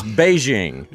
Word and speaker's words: Beijing: Oh, this was just one Beijing woman. – And it's Beijing. – It's Beijing? – Yeah. It's, okay. it's the new way everybody pronounces Peking now.